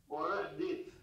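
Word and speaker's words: Orech din (עורך דין): Orech din.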